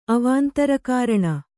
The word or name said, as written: ಅವಾಂತರಕಾರಣ